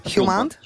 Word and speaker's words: Hjulmand: Hjulmand? – Hjulmand.